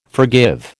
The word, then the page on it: forgive: American English